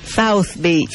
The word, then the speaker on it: South Beach: South Beach.